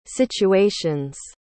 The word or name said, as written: situations